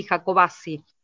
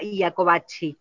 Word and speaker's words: Jacobacci: Jacobacci, el nombre que le pusieron al pueblo se pronuncia así, Jacobacci, nosotros los jacobacinos lo pronunciamos así. Sí, ciertamente, cuando estuvieron por aquí algunos familiares de, bueno, la persona que lleva, que le ha dado nombre al pueblo, digamos, nos dicen que en Italia sí, efectivamente era Iacobacci, como claro. se mencionaba. Pero bueno, está dentro de los términos aceptables la pronunciación.